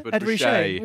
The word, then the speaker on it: Ed Ruscha: Well, I think it's, I don't know how to say it, is it Ed Ruscha? – Yeah, Ed Ruscha.